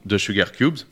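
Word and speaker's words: The Sugarcubes: The Sugar Cubes, je le dis bien.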